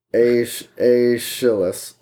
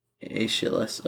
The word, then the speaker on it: Aeschylus: The man's name was Aeschylus. – Aeschylus, okay. – It's spelled Aeschylus, so Aeschylus.